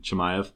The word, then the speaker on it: Chimaev: Chimaev. – It should be a fun one. Usman, clearly the more experienced fighter, you know. He has, 23 fights to his name in the UFC. Chimaev only has 12.